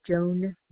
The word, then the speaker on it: Joan: Okay, name on the order would be Joan Knight, K-N-I-G-H-T. – Spell it, please. Spell the first and last name. – J-O-A-N, last name, K-N-I-G-H-T.